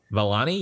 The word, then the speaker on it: Vellani: Also co-starring Iman, I hope I don't butcher her name, Vellanali? – Vellani? – Vellani, yes.